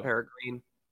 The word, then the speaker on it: peregrine: I've heard peregrine for a lot of my life, but I've also heard people say peregrine. But I believe it's peregrine. – Okay, and that's not how it's spelled, but I think it's pronounced peregrine. – I have no reason to argue against you. – Think about it like this. Dopamine has the exact same ending.